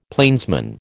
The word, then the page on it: plainsman: American English